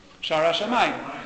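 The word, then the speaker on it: Sha'ar HaShamayim: He says the expression of Babel, Bab Elim, in Hebrew, that means, well, the gate of the God, how would you say that in Hebrew? Shara Shamayim. Correct. That expression appears once in all of Tanakh.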